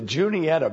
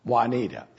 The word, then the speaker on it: Juniata: J-U-N-I-A-T-A. People will call it Juanita. It's not Juanita.